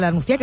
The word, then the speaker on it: La Nucía: La Nucía, que no sé si lo he dicho bien porque de esas cosas se encarga siempre mi compañero Nico Van Loy.